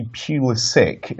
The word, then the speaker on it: Pulisic: But, oh yeah, by the way, I have it on good authority, it is actually Pulisic instead of like Pulisic. Because apparently it's the American thing, and it is pronounced Pulisic.